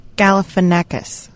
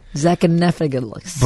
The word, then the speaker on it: Galifianakis: You were putting an extra syllable in there, as you just did. – Zachanephagus.